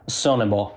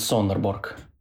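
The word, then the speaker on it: Sønderborg: Так, а, значит, по-датски по-датски он называется Сонебо. А по- по-русски можешь сказать? Сонебо. А если на немножко немецкий манер произносим, то это будет Сонерборг.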